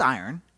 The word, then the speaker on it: airn: The iron, which is spelled A-I-R-N, but that's iron, right? – I want to see this.